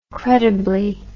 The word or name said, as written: credibly